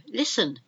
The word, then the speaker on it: listen: Listen. You've got to listen, but not with that T.